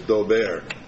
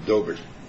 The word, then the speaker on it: Daubert: I insisted that it was Daubert. And then I was informed that they themselves pronounce it Gobert.